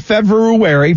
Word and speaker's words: February: And I've heard February. People say that February. February. People say February, and it's actually February. Because it's B-R-U-R-U, not B-U-R.